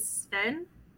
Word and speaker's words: Sven: And I think it's Sven, not Savine. – Sven, yeah. – I don't think you actually pronounced the E.